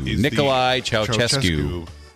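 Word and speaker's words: Nicolae Ceaușescu: Nikolai Ceaușescu.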